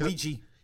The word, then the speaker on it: Ouija: Um, and so after seeing this, actually, I think just today or yesterday, I went and watched uh, Uji Uja. How do you pronounce? Oh, Ouija. Ouija, uh, like, and because uh, I never, because the first one sucked, and I never went to see the sequel.